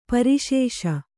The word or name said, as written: ಪರಿಶೇಷ